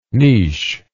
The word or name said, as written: nis